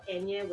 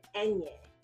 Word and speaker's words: Enye: And then we changed, and then a lot of people want to know, why is it E-N-Y-E? It's because literally that's the phonetic spelling. So if you look it up, it's E-N-Y-E. So people would pronounce it right, right?